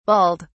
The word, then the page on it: balled: British English